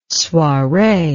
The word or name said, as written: soiree